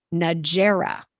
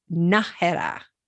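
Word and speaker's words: Nájera: The next one is often pronounced in English, Najera, which is not correct. It's Najera.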